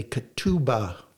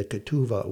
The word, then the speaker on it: ketubah: So the two families sup together, and if the bride is agreeable, they write up a covenant called a ketubah.